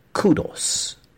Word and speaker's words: kudos: Kudo or kudos, first of all, or is it kudo without the Q, the U-Y-U in it? Well, according to the dictionary, we have two allowed pronunciations. The word actually appears as K-U-D-O-S in the dictionary, right? K-U-D-O-S. And the British pronunciation is kudos, kudos. The American pronunciation is kudos, kudos. Just a slight variation there.